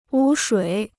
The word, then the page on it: 污水: wū shuǐ